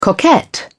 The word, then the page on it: coquette: British English